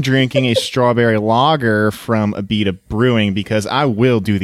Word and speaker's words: Abita: No, that's how you pronounce Abita. I know it looks like Abita, but you know how we do down here in Cajun funk.